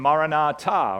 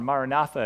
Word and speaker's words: Maranatha: How did the Greeks know words like Abba and Amen and Maranatha, or Maranatha as you might pronounce it?